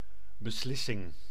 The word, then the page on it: beslissing: /bəˈslɪsɪŋ/